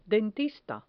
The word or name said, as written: dentista